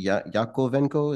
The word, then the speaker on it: Yakovenko: Pounding the Table, you've had Anthony Scaramucci, the founder of Solana, and Tuli Yakovenko, is that how you say his name? – Mm-hmm.